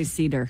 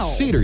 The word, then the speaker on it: Seder: It's spelled S-E-D-E-R. – I would say cedar. – Looks like cedar, right? It's a noun. It's pronounced seder.